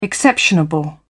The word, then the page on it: exceptionable: British English